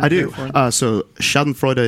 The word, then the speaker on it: Schadenfreude: It's pronounced Schadenfreude. It's German. – Schadenfreude, yeah, huh? – Schadenfreude.